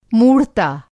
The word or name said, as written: मूढ़ता